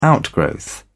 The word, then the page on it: outgrowth: British English